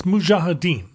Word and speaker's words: Mujahideen: Is it Mujahideen or Mujahideen? It's Mujahideen. – The J is first, but I don't know if I'm saying it right.